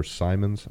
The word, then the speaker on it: Simons: Really, he's just got to beat out Anfernee Simmons, or Simons, I believe is the proper pronunciation there.